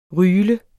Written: ryle

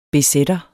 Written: bz'er

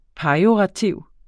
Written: pejorativ

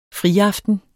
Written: friaften

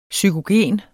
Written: psykogen

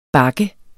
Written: bakke

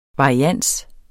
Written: varians

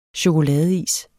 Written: chokoladeis

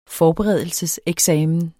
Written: forberedelseseksamen

